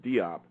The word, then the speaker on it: Diop: I think that first of all, his name is Sheikh, but not with an S, with a C, C-H-E-I-K-H. Anta, I'm not sure what that means, A-N-T-A. – Mm-hmm. – And Diop, I think most people pronounce it Diop, is spelled D-I-O-P.